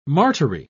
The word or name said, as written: martyry